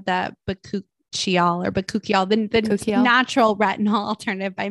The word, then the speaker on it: Bakuchiol: Bakuchiol, and then I love their body polish scrub.